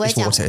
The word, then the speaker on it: water: It's water, it's water. We pronounce the T.